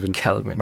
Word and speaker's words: Calvin: Man kann über, wie spricht man es jetzt eigentlich aus, Kelvin oder Calvin? – Calvin.